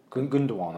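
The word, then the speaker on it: Gündoğan: So, yeah, I also thought like you say, I can never say Gundogan. – Gundogan, I think. – Gundogan. – I think it's pronounced.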